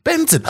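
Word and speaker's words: BNXN: And then Colors, they said Bixen. Then they put parentheses, pronounced Benson.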